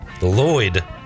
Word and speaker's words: Lloyd: Lloyd. Because people forget. – L-L-O-Y-D.